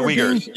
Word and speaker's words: Uyghurs: And now the Uyghurs, is that how you pronounce the people? – Yeah, the Uyghurs, Maybe genocide of some kind, at least oppression.